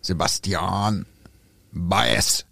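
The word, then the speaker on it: Sebastián Báez: Ach so, dann wird der gar nicht so französisch ausgesprochen. Sebastian Baez.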